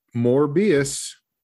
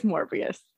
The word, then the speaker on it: Morbius: Morbius? Is that how you say it? The Jared Leto one? – Morbius. – I think it's Morbius.